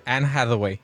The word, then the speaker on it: Anne Hathaway: Anne Hathaway.